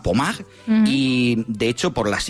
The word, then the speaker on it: Pommard: Si las buscáis, Pommard, se escribe Pommard, como si fuera en castellano, pero Pommard, pues allí en Francia, hablamos de unas imágenes que podrían pasar.